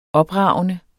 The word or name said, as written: opragende